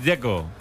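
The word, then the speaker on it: Džeko: Porque DZ, ¿cómo se pronuncia? – Checo. Bruno dice que es Checo. – Como tirando a J, Checo o algo así.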